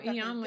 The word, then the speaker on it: Iyanla: Ayanla, I never say her name right, Now. that's one thing. No, I Iyanla, did I say it right? Yeah, I think you said it right.